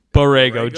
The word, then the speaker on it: Borrego: And then Charlotte coach, I never know how to pronounce his name, but Borrego, James Borrego.